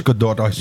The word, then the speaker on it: Gadot: It's Gadot. I saw her being interviewed on Jimmy Kimmel. She schooled him on how to say the name.